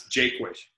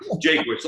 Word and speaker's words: Jaquish: You know, you said it right the first time. It's Jaquish. – Jaquish. – You changed it later on. Jaquish, like Jake and Wish.